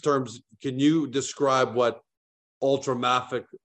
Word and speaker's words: ultramafic: The first thing I'll do is correct your pronunciation, ultramafic.